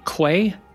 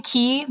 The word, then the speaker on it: Quay: It's funny because you can also be pronounced Key if you're from the UK. So sometimes people call me Key, but yeah, it's Quay.